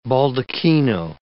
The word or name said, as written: baldachino